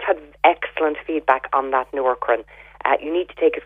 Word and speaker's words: Nourkrin: My favourite one for thinning hair is the Noorkrin. It's spelled N-O-U-R-K-R-I-N.